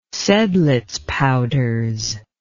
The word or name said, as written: seidlitz powders